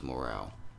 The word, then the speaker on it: Mr. Morale: Because I was reading it wrong. It's not Morrow, it's Morale. Mr. Morale and the Big Steppers. That's how you say it.